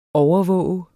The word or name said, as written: overvåger